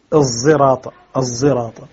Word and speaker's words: الصراط: فخلف يقرأ بالصاد المشم زايا، والإشمام هو الخلط، أي بخلط صوت الصاد مع صوت الزاي حتى يصير صوتا صوتا ليس بصوت الصاد، صوتا فرعيا ليس بصوت الصاد ولا بصوت الزاي. فلذلك صار كما نقول الزراط، الزراط وزراط،